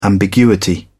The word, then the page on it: ambiguity: British English